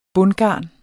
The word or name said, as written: bundgarn